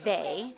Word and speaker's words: they: If I'm teaching the A, long A sound, then I would teach words, sight words, or, you know, with that sound, like the word they is spelled T-H-E-Y. E-Y is a code or a spelling for the A sound.